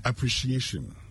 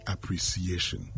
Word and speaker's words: appreciation: Just as you say appreciate, addition to it, it becomes appreciation. Okay? It is not appreciation. Well, sometimes, you know, you may want to... sometimes you think that the best way is because you see the C in there, please. That is not how to say it right.